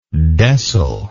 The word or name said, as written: decyl